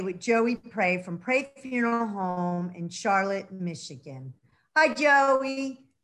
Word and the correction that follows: Charlotte: We pronounce it Charlotte, the English way. – Charlotte.